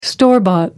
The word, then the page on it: store bought: American English